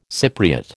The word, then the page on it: Cypriot: American English